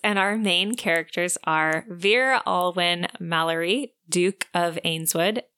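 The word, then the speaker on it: Vere: And so that's why I'm saying Vera, because perfect. – It's like a shortened verity.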